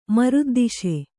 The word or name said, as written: ಮರುದ್ದಿಶೆ